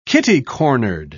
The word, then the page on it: kitty-cornered: British English